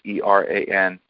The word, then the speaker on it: Eran: It's Eran.